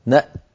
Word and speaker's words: نأت: هكذا يقف عليها نأت بهمزة ساكنة يتلوها تاء شديدة مهموسة